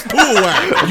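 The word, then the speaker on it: Puawak: So you know how all the Poo-O-Wack so far have had, like, the big bug eyes? – Yeah. – Don't laugh. Their name is Poo-O-Wack.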